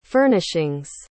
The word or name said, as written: furnishings